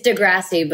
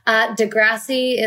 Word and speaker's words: Degrassi: It's Degrassi, but Degrassi. Americans say Degrassi for some reason.